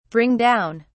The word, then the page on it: bring down: British English